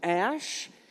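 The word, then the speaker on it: Æ: The A-E is pronounced Ash.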